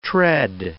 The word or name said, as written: tread